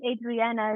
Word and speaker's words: Adriana: I just laughed a little bit hearing my Kiwi accent, like Adriana, and then you're like, your beautiful Portuguese accent of how your name's meant to be pronounced. I'm like, oh my God, my accent. – No, don't worry. I find myself saying Adriana as well because I feel it's easier because most of people speak English.